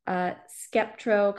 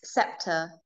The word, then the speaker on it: Sceptr: So tell our audience a little bit about your story and why you started your cosmetic company, Skeptro Cosmetics. Did I pronounce it right? – It's Scepter, actually.